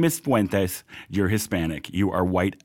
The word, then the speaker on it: Fuentes: Kanye West went on the Alex Jones show with, how are we pronouncing his last name? – Fuentes. Like it's supposed to be pronounced when you're, you know, a Latino immigrant, yeah.